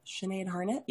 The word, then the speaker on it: Sinead Harnett: Sinead Hart. – Harnett? Sinead Harnett? – Yes. Harnett? Oh, I said Hart. – Net? – I even read it wrong.